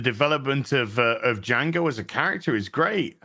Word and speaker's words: Django: I was just gonna say that what he says, "Fuck you, Django," that he shoots him, says the D is silent hillbilly.